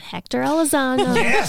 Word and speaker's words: Elizondo: If that character is the same, that means that Hector Elizondo. – It makes sense. – Did I say his name right? – Yes. Yeah.